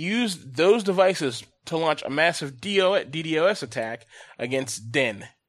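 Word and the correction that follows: Dyn: So I think I'm saying that right. – Dyne. – There you go.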